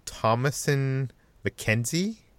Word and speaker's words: Thomasin McKenzie: This is a movie starring Ben Foster and, I'm going to pronounce this name wrong, Thomasin McKenzie? – Yes.